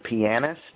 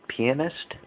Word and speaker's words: pianist: Some people pronounce it pianist. I think it sounds too much like penis, so I skip that. I say pianist because I pronounce it piano, so why would I say pianist when piano is pronounced piano? Adding an IST to the end of it should be pianist.